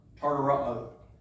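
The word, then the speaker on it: tartaroō: Tartaro is how you pronounce it.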